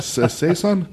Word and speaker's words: saison: Oh, this Seashon. Oh, sorry, Saison.